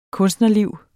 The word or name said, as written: kunstnerliv